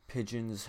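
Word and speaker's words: pidgin: I am your host, Vincent, and in this episode we're going to be talking about pigeon, I think is how it's pronounced. Actually, let's listen to this. – Pigeon. – Okay, yeah, I got it right.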